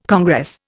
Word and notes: congress: Indian

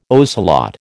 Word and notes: ocelot: American English

